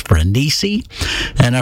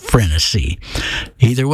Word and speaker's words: Frenesi: I've heard this one pronounced two ways. I've heard it pronounced Frenesi, and I've heard it pronounced Frenesi. Either way, it's a great tune, Glenn Miller and his orchestra.